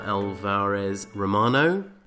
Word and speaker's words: Alvarez-Romano: Okay, so we have with us here Lola Alvarez-Romano.